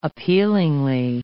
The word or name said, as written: appealingly